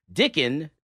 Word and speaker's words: Dicken: Elisjsha Dicken. – Say his name. – Dicken. – Dicken. – Yeah. – Oh, I'm saying with the S at the end? – No S.